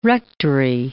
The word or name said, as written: rectory